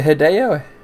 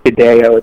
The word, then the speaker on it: Hideo: Is it Hideo? – I was pronouncing it wrong. It's pronounced Hideo.